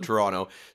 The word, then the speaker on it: Toronto: but Canadians tend to say, like, I'm from around Toronto, the suburbs of Toronto, so we don't pronounce the last T. We say Toronto and Ottawa.